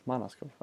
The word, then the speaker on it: Manaskov: Manaskov, är det så det uttalas. Du får kolla med Grosch, Grossan eller vad heter han? – Manaskov, Manaskov, ja.